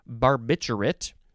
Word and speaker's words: barbiturate: A crystalline barbiturate. I wanted to say barbiturate, but there's an R in there.